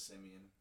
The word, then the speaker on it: Simeon: I believe it's Simeon. Simeon? Simeon? How do you pronounce S-I-M-E-O-N? Someone tell me. – I thought it was Simeon. – Simeon. It's a fun name.